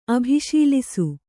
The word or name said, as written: ಅಭಿಶೀಲಿಸು